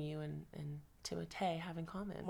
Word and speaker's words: Timothée: Timothy Stand. I can't. Timothée, sorry. – Is that how you properly say his name? – Well, as you know, I'm obsessed with Dune press tour. – Yeah. – So he was explaining in it. – It is Timothée.